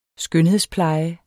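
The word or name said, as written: SKØNHEDSPLEJE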